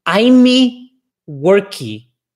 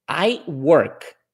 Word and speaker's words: I work: I'm worky. Quando elas querem dizer eu trabalho, se você quer dizer eu trabalho, você vai dizer I work. I work.